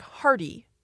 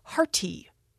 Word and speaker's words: hearty: But when you listen to a word like hearty, we don't actually, in American English, pronounce it with a T. We don't say hearty.